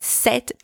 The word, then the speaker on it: cet: If the noun is also masculine singular, but starting with a vowel, then you're going to use C-E-T. And I'm going to pronounce it set because there's a vowel following after. For example, cet arbre.